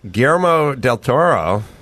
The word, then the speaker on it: Guillermo del Toro: Guillermo del Toro.